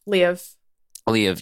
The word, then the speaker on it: Liev: Ryan's right. Liev. – Leave. Yeah, so Leave.